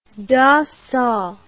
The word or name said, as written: Dassault